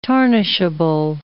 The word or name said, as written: tarnishable